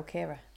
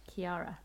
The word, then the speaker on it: Kiara: Are we pronouncing this Kiara or Kira? – Kiara. – Oh, okay. Like Kiara and Cova in Lion King. – Because she told us in the book that her name had three syllables.